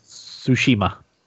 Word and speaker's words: Tsushima: Is that how it's pronounced? Tsushima? – Tsushima. – Tsushima. – Tsushima. – Tsushima. – Tsushima. – Tsushima. – Yeah, close enough.